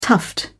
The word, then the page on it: tuft: British English